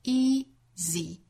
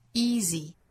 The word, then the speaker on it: easy: 这个词我们注意啊，读成 easy, easy, easy, 很多以字母 y 结尾的单词都是发这个紧张的，但是是短音的 e 的发音，像 busy, b-u-s-y, busy, busy, busy。再比如说 many, m-a-n-y, many, many, many。